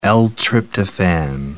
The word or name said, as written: L-tryptophan